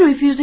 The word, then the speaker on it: refuse: OK, 我们注意动词拒绝的发音是 refuse, 那么同样是 R-E-F-U-S-E, 还可以读作 refuse, 注意发音 refuse, 作为名词表示垃圾废物的含义，注意它这个不可数名词，大家一定注意了，动词读作 refuse, 表示拒绝，名词是 refuse, refuse, 是个不可数名词，表示垃圾和废物。